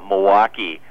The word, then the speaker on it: Milwaukee: You got that Milwaukee.